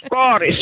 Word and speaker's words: Karis: Karis säger jag, det är på mitt uttal.